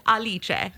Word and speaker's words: Alice: I think if we're going with the French pronunciation of Vachon, yeah, I'm going to go with the Italian pronunciation of my character's name and be known as Alice.